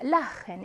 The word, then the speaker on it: Lachen: Lachen ist gesund. 웃음은 건강에 좋습니다라고 했고요. 모음 아, 오, 우 또는 복모음 아우 뒤에 뭐가 나오면 플러스 7하이면 이때 이 7하는 무슨 발음 낸다라고 했나요? 흐 발음을 낸다라고 했어요.